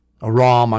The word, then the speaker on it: Aram: We read in 2 Kings 8:13, then Hazael said, that's the king of Syria, Aram, a ROM, I guess I should say. Aram sounds like a redneck pronunciation.